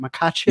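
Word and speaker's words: Makhachev: So that's I think, the newest guy to come on stage with this Sambo thing is Makachev. I think is how you say that. Makachev.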